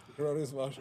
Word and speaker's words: Hieronymus Bosch: I'm trying not to butcher his name, but Hieronymus Bosch. – Hieronymus Bosch.